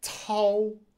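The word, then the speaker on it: cao: So that would be tau and tai, tau, tai. So we talked about how C can be pronounced like the T-S in cats. Cats.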